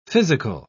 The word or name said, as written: physical